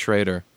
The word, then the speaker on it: Schroeder: Jay Schroeder. – Schroeder. – Schrader. – Yeah. Was spelled Schroeder. – Quarterback? – Pronounced Schrader.